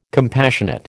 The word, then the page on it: compassionate: American English